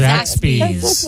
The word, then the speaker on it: Zaxby's: I can't even pronounce Zaxby's.